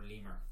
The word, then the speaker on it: Limor: Yes, this is an acronym for Less is More, so mm-hmm. LIMOR or Lemur.